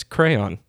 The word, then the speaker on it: croyant: It's spelled differently. C-R-O-Y-A-N-T. And I'm not a scholar in French. – Okay. – But it definitely sounds like crayon.